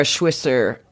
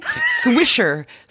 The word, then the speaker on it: Swisher: On the Pivot podcast, Kara Schwisser, sorry, I said her name wrong. Kara Schwiss. I can't say her name. Swisher.